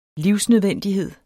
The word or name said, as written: livsnødvendighed